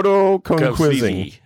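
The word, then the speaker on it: quomodocunquizing: It's called Quomodo conquizing. – Oh. – It took me forever to figure out the pronunciation because I kept wanting to go Quasimodo.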